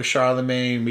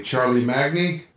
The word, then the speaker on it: Charlemagne: By the way, Charlemagne is supposed to be in this movie. Charlie Magni, that was the pronunciation that Bill and Ted went with, but they cut him out.